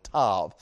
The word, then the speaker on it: Tav: Tav has that V sound on the end of it, Tav.